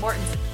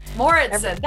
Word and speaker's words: Mouritsen: Hello, and welcome to this episode of Share Your Hotness and my guest today, Steph Mouritsen. Did I say that right? – Almost. – Okay. What's the correct way of saying it? – Mouritsen. – Mouritsen.